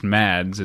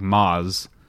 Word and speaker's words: Mads: that it's not pronounced Mads; it's like Maz.